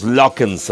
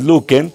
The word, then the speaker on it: Luken: Fíjense que nosotros toda la vida hemos dicho los Lockens, así como muy, muy en inglés. Realmente estos muchachos es un trío de hermanos de Zacatecas. Y bueno, pues nada que ver con la pronunciación en inglés. Su apellido es Luken, y bueno, pues ellos grabaron esta canción.